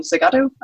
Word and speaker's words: Segado: My name is Melanie Segardo.